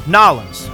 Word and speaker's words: New Orleans: Now, if you're into haunted places, then look no further than New Orleans, Louisiana, or Nawlins, as the locals say it.